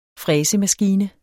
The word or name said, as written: fræsemaskine